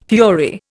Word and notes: fury: Indian

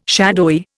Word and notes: shadowy: Indian

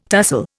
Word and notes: tussle: Indian

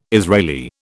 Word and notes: Israeli: British English

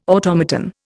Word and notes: automaton: Indian

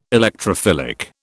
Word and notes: Electrophilic: British English

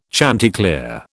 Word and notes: chanticleer: British English

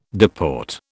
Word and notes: deport: British English

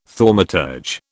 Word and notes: thaumaturge: British English

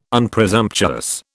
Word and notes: Unpresumptuous: British English

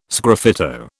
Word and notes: sgraffito: British English

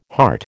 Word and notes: heart: American English

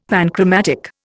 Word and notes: panchromatic: Indian

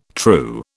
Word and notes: true: British English

